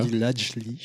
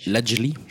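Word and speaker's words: Ladj Ly: Je ne sais pas si je le prononce bien déjà. Ladjili. – Ladjili, d'accord. Bon bah voilà, déjà je me suis trompé.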